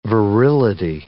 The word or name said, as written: virility